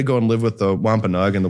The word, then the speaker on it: Wampanoag: So he had to go and live with another tribe, the Wampanoag. I can't pronounce it. I'm sorry. – No, you hit it. You hit a home run on that one.